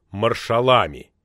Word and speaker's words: маршалами: Феликс, как и сам генералиссимус, сделал ударение на последнем слоге – маршалами.